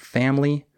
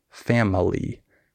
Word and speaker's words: family: Also, uh, with um, skipped syllables, for example, in the word family, the I is silent. We don't say family. We say family.